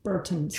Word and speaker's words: Bertens: So, can we talk about the Sarah Irani Kiki Bertens match, however you pronounce her last name? – Yeah. – The last... – Bertens.